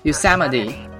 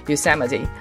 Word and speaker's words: Yosemite: 首先是我要纠正自己的发音，第一个是那个优胜美地就是 Yosemite Yes, Yosemite，我专门去查了。 OK，我要更正，就是苹果的最新的那一版 OS X 叫做 Yosemite Yosemite。